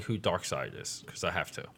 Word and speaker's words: Darkseid: I'm like, this is, and I'm like, it's pronounced S-I-D-E, like, because it's spelled S-E-I-D. – Mm. – Like, so it's like Darkseid.